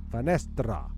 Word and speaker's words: finestra: is the Italian for window is Finestra.